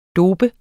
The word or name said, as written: dope